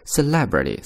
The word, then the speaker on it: celebrity: 注意啊， celebrity 这样的一个词呢，重音是在后面的音节上，所以读作 celebrity。